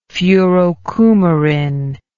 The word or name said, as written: furocoumarin